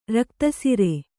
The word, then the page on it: ರಕ್ತಸಿರೆ: Kannada